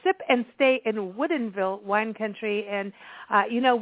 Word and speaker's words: Woodinville: Am I saying this correctly? Woodinville Wine Country. – Yes. Yes. – Woodinville.